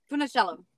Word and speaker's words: Funicello: My guest today is Jennifer, help me say your last name, Funicelli? – Funicello. – Funicello. Like lemoncello.